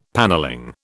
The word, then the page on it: panelling: British English